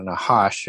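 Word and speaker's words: Nahash (נחש): And, you know, what the Nakash, or the Nahash, as it's pronounced in Hebrew,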